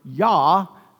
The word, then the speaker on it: Yah: And if you hear the word praise the Lord, right, that Jah on the end of it, or Yah, appropriately pronounced, is the short for Yahweh, praise the Lord.